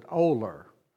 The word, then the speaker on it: Olar: And that town is spelled O-L-A-R, and we pronounce it Oler.